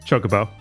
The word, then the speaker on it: Chocobo: Chocobo. – That's the correct vowel to start that word with. I don't know who went into that studio and said, we're going to pronounce it chocobo because I like chocolate, but they need to die.